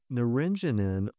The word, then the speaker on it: naringenin: It's found especially in citrus fruits, naringenin, N-A-R-I-N-G-E-N-I-N, naringenin. Had you heard of that one? – I had not. – No news to me as well. And this naringenin, also another phytochemical, so it's a chemical compound found in plants, and it's got all kinds of benefits.